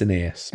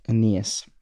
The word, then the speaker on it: Aeneas: How do you pronounce this? Aeneas? – I think it's Aeneas. – Aeneas. – But, it's a... – And Dorcas. – He's named after a hero of Greek mythology. – Aeneas, okay.